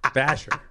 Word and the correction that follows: Bashir: Bashir. – Julian Bashir. – Terrorist. – Dr. Julian Bashir.